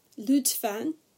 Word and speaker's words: lütfen: Lütfen is how you say please, and lütuf is how you say grace.